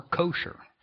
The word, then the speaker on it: kosher: Jews will only participate in things and eat certain things that are kasher or kosher, depending upon how you want to pronounce it.